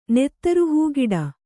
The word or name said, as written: ನೆತ್ತರುಹೂಗಿಡ